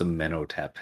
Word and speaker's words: Amenhotep: No, the Amenhotep is very much so a very real pharaoh of Egypt, but that's how it is pronounced, Amenhotep, and I know that from the Mummy series.